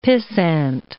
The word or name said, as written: pissant